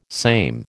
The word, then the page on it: same: American English